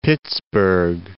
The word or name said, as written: Pittsburg